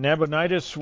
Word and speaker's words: Nabonidus: Yeah, Nabonidus, yeah, that's it, yeah.